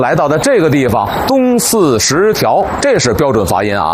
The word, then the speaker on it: 东四十条: 您肯定听过有人念成东四十条，其实啊应该念成东四十条，因为过去北京城啊从这个东四往北有 14条东西走向的胡同，就是东四头条到东四 14条。这东四十条呢就是其中的第 10条胡同，但现在啊已然拓宽了。